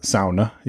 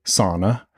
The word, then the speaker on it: sauna: And the other thing I learned was that as much as we love saunas here in America, which, by the way, that is how you pronounce it, sauna, you don't say sauna, it is sauna, as much as we love them here, it doesn't compare to Finland.